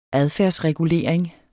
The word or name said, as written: adfærdsregulering